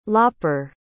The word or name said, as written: lopper